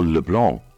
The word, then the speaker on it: le houblon: Hops, or le blanc, as it is pronounced in French, is spelt le, L-E, blanc, H-O-U-B-L-O-N.